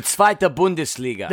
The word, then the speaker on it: zweite Bundesliga: He did leave my Bundesliga club, Union Berlin, but that was when they were in Bundesliga 2, if I pronounced it correctly. – Die zweite Bundesliga. – There you go.